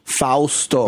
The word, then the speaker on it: Fausto: Like it's not like pasta. – Fausto. – It's not like Bosco syrup. Fausto. Foul. It's like owl and toes.